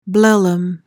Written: blellum

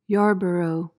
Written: yarborough

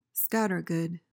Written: scattergood

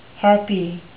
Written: harpy

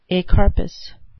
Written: acarpous

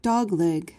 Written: dogleg

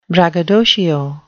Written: braggadocio

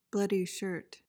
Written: bloody shirt

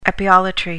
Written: epeolatry

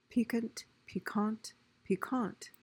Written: piquant

